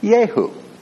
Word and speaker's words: Jehu: A man named Jehu, real name, Jehu, it's spelled J-E-H-U, it's pronounced Jehu.